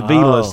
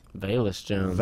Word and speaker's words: Velus: And it's V E L U S. That's oh, Velus all day, right? Velus. But uh, it's Velus. Velus Jones. Velus. That's the pronunciation.